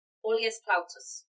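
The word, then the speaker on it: Aulus Plautius: And he was under the command of Aulus Plautus, which I do know that's how it's pronounced because I remember hearing it once and liking that name.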